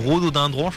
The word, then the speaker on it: rhododendron: Tu remarqueras que je dois dire rhododendron, je crois que j'ai dit trois fois la même syllabe au milieu.